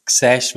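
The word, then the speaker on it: X Æ: Quasi, also XAE, also das griechische AE letzten Endes, also Xash wird das ausgesprochen, A12, das ist Elon Musks Kind.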